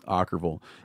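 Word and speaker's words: Okkervil: Is that how you say it? Ockerville? – Ockerville. Yeah, huh.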